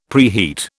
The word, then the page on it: preheat: British English